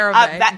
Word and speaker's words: CeraVe: CeraVe? Is that how it's pronounced? – Yep, CeraVe.